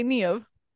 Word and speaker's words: Niamh: She said, oh, so you say it wrong. – Yeah, that's literally how it felt like. She's like, oh, Niamh. I was like, well, I mean, like, if I was pronouncing it Niamh, I would have said Niamh, but I don't, Niamh. So I say Niamh.